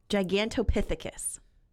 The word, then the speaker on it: Gigantopithecus: Gigantopithecus.